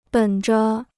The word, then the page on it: 本着: běn zhe